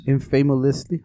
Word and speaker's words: infamously: 30 years ago, Atari dumped millions of copies of its infamously. How do you say that word here? Say it right here.